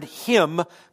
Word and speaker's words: hymn: Why do we have a silent N on the end of hymn? Because the Greek word would be H-U-M-N-E-O. There's a silent N that we inherited from another language, so blame it on the Greeks.